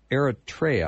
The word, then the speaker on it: Eritrea: And Ruth, I have to think of this little place in Africa called Eritrea. Am I saying that right? – Yeah, yeah.